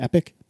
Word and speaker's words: EPYC: Is that supposed to be EPIC platforms? – That's how I pronounce it when I read it.